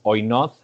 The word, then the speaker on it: Oinoz: And the name is Oinoz. It's spelled O-I-N-O-Z. – O-I-N-O-Z.